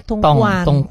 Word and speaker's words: Dongguan: Dongguan tulisannya ternyata baca Tongguan.